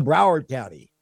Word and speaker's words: Broward County: And Broward County issued a... – Broward County.